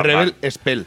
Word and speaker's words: Rebel Spell: Es la Rebel Spell. – Sí, siempre lo digo mal, disculpadme, podéis corregirme.